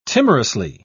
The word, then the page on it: timorously: British English